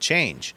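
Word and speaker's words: Chanje: He did a big, long investigative piece on a startup called Change. I think that's how you pronounce it anyway. It's like Change with a J instead of a G.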